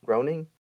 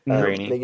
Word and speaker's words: Groening: I'm sorry if I pronounced the name of Matt Groening." – Groening.